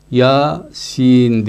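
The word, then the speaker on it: Yasin: Biz bunu y ve sin olarak değil de telaffuzlu olarak ya sin diye telaffuz ediyoruz, öyle okuyoruz.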